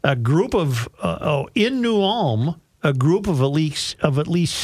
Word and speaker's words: New Ulm: New Ulm. – New Ulm.